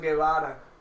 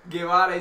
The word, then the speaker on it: Guevara: Guevara is G-U-E-V-A-R-A.